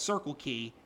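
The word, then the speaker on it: Circular Quay: Only reason why they have a place called Circle Key and it's spelled Q U A Y, and that's the only reason why I said Qudos Bank, just because.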